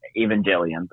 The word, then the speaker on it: Evangelion: Evangelion, first off.